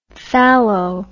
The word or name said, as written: thallo